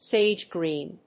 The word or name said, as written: sage-green